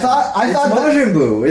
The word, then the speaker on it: Majin Buu: Mojang Buu? – It's Majin Buu, isn't it? I said it right.